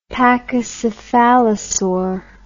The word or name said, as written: pachycephaosaur